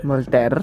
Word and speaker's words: Voltaire: Y esta frase está atribuida a Voltaire, no sé si lo estoy pronunciando bien. – Voltaire, bien, muy bien.